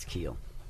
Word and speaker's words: Kuehl: And, you know, James, is it pronounced Keel? Let's get the pronunciation right off the bat. Is it Keel? – Yes, it's Keel.